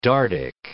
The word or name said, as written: Dardic